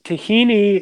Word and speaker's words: tahina: So what hummus is really the marriage between chickpeas and tahina, as you would pronounce in Hebrew. But, tahini is just sesame seed paste.